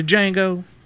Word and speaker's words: Django: The Django. – The Django. The D is silent.